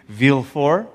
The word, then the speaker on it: Villefort: his name is spelt like it's Villefort, but I think the French pronunciation is Villefort. Anybody? Yep.